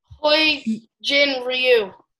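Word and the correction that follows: Hyun-jin Ryu: I don't know how to say his name. – All right, Hyunjin Ryu.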